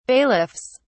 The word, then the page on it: bailiffs: British English